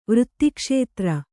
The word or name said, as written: ವೃತ್ತಿಕ್ಷೇತ್ರ